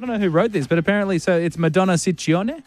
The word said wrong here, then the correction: Ciccone: Ciccone. What is it, sir? – Well, in Italian, it works the other way. So the double C's are hard C's and the singular C's are soft C's.